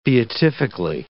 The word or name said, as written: beatifically